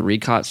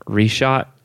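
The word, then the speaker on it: Richat: Depending on who you are, you might hear some people pronounce it Reshot. Structure, but no. Ricott sounds a lot better to me, so I'm gonna ignore what everybody else says.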